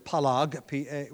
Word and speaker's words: palag: The Hebrew word is palag. We would spell it probably P-A-L-A-G to pronounce it in Hebrew.